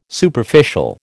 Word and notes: Superficial: American English

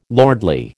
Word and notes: lordly: American English

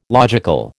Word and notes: logical: American English

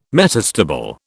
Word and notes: metastable: British English

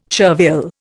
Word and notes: chervil: Indian

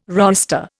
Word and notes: roister: Indian

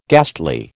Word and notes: ghastly: American English

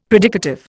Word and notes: predicative: Indian

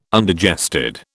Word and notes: Undigested: British English

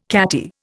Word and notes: catty: Indian

